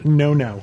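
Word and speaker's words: Nono: I was pronouncing it Nano's. Chrissy 2803 has written to me and said, I don't know if anyone else has told you this, but the name place is pronounced no-no.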